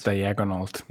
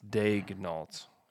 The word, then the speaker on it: Daigneault: I thought it was Diagonault. – Yeah. It's not Diagonault. It's not Daigneault. Not Dag. Please stop with the Daigneaults. It's Daigneault. It's on there. D-A-Y-G is the pronunciation.